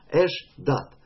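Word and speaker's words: Эш-дат (אשדת): Эш-дат. Читается как два слова, а пишется вместе, слитно.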